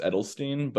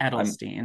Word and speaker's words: Eidelstein: No, there's a there's a weird I there that shouldn't be there. It's pronounced Edelstein, but Edelstein.